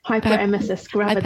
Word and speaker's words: hyperemesis gravidarum: De- Hy- hyperemesis gravidarum.